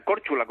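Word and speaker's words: Korčula: Córcula sería Córchula, como dicen ellos. Córchula. Es una C que tiene un pequeño circunflejo arriba, es una especie de CH.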